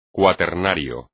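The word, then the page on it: cuaternario: español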